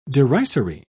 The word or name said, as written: derisory